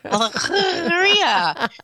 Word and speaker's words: Alegria: I'm pronouncing it wrong. Alegria. I need to have the... It's funny. I have neighbors. They're Cuban. – Uh-huh. – And their ship is named Alegria, and they say it correctly. I cannot roll my R's.